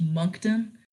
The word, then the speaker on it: Moncton: Apparently it's Moncton.